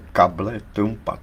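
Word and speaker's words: cable trampa: V podstatě tuto situaci popsal přesně jako takzvaný plán kable Trumpa. – A to se vyslovuje možná kable Trump, ne? Ne, ne, ne, to se vyslovuje kable Trumpa, to je španělský termín značící nástražní drát k mině.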